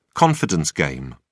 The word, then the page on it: confidence game: British English